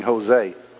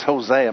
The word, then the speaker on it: Hosea: The last time I preached out of this, I kept saying Hosea. But it's Hosea. My wife corrected me.